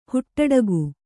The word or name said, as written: ಹುಟ್ಟುಡುಗೆ